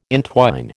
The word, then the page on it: intwine: American English